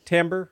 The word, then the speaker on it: timbre: Timbre. – Timbre? – Yeah, okay. It's timbre, even though it's spelled T-I-M-B-R-E.